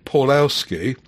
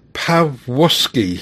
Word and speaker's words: Pawłowski: Marek, which I've always said Paulowski, is actually pronounced Pawłowski, he says, where W is V and L is W. And in defiance of logic, the second W is ignored, because I think it should be, going by the rules, Pawłowski. – The second W is a silent V.